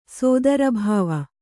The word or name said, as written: ಸೋದರಭಾವ